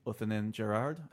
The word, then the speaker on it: Othenin-Girard: Bitterman is still credited, but another co-writer on the movie is director Dominique Othonen-Gerard. I think I pronounced that right.